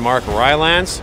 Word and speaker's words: Rylance: And Mark Rylance, did I say that right? – Yeah, that's right.